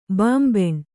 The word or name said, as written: ಬಾಂಬೆಣ್